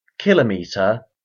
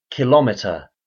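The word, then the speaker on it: kilometre: There are two common pronunciations for the word, kilometer and kilometer. The former pronunciation follows the general pattern in English, whereby metric units are pronounced with the stress on the first syllable, and the pronunciation of the actual base unit does not change irrespective of the prefix.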